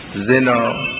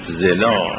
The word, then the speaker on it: زنا: زنا دو جور عرض کنم که تلفظ میشه.